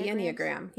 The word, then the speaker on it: Enneagram: But what we're talking about is a thing called Enneagrams. Is that how you pronounce it? – Yeah, the Enneagram.